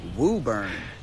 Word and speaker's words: Woburn: In fact, it might even Woburn. – Have an invisible letter. Because this is pronounced Woburn.